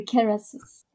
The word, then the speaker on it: Kerasus: And that is, is the, I don't know if you, do you call it Carassus wine? That's like the brand name? – Yeah, it is the brand name. – Am I pronouncing it correctly? – Yeah, correctly. Carassus.